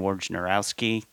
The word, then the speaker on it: Wojnarowski: Adrian Wojnarowski, did I get that right, Trey? The pronunciation? – Yeah, okay. Woj.